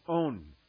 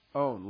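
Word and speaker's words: Own (ὤν): In Greek, it's pronounced own. O-N in English. Own, long O. Own.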